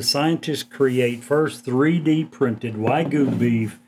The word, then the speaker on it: Wagyu: Yeah, Wagyu steak.